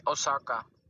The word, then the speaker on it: Osaka: Oshina? I have no idea how to say her name. Let's look her up. Maybe Google can help me and pronounce her name. Okay, let's see. – Naomi Osaka.